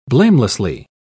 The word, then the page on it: blamelessly: British English